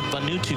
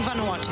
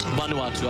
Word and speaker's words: Vanuatu: And I think he was involved with Vanuatu cricket as well at some point. – Are you meaning Vanuatu? – Vanuatu, I beg your pardon.